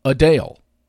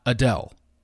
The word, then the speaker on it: Adele: And she says the correct pronunciation of her name is Adele, not Adele.